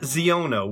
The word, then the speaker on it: Xiono: It's an animated series about Kazuda Xiono. We are working on the pronunciation. That's X-I-O-N-O, Xiono, perhaps.